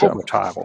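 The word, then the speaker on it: Ciobotaru: Jetzt hoffe ich, dass ich ihren Namen richtig ausspreche. Ciobotaro. – Ist das richtig? Ciobotaro, glaube ich. – Ciobotaro, ja. Okay.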